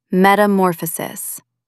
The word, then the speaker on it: metamorphosis: Put the stress on the third syllable, metamorphosis. Try saying it.